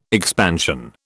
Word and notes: expansion: British English

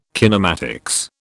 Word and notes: kinematics: British English

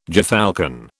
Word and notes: gyrfalcon: British English

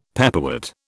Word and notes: pepperwort: British English

